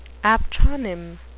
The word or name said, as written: aptronym